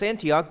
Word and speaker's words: Antioch: Now, if you don't know how to pronounce Antioch, just imagine that you had an aunt and her name was Ock.